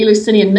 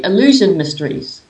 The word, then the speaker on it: Eleusinian: And I had thought that, you know, the Eleusinian mysteries, the way I pronounced it to myself originally, I used to say illusion mysteries.